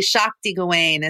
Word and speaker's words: Shakti Gawain: But can you tell us about Shakti Gawan and what it was like working with? That incredible force of nature. – Yeah, it's actually Shakti Gawain is how you pronounce it, Shakti Gawain.